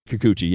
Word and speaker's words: Kikuchi: Kikuchi.